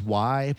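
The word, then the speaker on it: way: It's pronounced Y, but it's W-A-Y.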